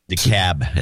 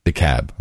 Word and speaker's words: DeKalb: DeKalb, as they pronounce it. – Yeah, I like DeKalb. It's a weird word. K-A-L-B. DeKalb. Okay.